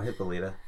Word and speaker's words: Hippolyta: Is it Hippolyta or Hippolyta? – No, Hippolyta.